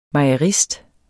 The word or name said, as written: mejerist